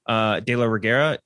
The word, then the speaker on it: de la Reguera: And that's like, I think the one thing that stands out to me is that I think I'm going to try and pronounce the name correctly. Ana de Reguera, De La Reguera, like who we recently saw in Army of the Dead.